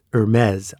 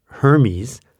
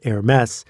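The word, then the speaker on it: Hermès: Because every time I see that word, which should be pronounced Hermes, I think, but it isn't, or like the Greek god Hermes, nope, that's not it either, it's Hermes, I'm like, this is too much trouble.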